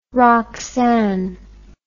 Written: roxane

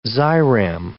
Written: ziram

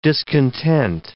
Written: discontent

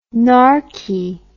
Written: narky

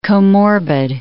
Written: comorbid

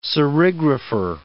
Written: serigrapher

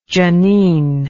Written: jeanine

